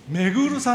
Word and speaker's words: 彗: お名前を遠藤めぐるさんとおっしゃいます。めぐるはですね、ハレー彗星の彗と書いてめぐると読むんだそうです。